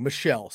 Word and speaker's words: Michel: My bad. I said Mitchell. I'm sorry. I read that wrong. Michelle.